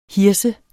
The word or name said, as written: hirse